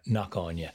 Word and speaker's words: Cnoc Aine: That's the English translation of Cnoc Aine or Knock Aine.